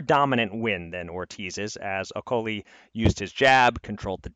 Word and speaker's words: Okoli: I'm learning the correct pronunciation. It's Okoli, which is just devastating as someone who is enjoying making okoli-dokoli jokes.